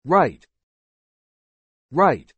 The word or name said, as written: right